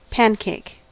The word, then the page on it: pancake: American English